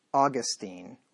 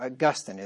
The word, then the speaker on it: Augustine: Nor is this heresy a modern thing, for it existed in the time of Austin, that is, Augustine, or Augustine is how you really pronounce his name. Most people say Augustine, the famous Catholic back in the 4th century.